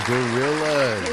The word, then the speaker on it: Gorillaz: Is it pronounced as gorillas? – You got it. The gorillas.